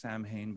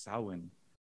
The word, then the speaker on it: Samhain: I know it looks like it should be pronounced Samhain, but it's pronounced Samhain.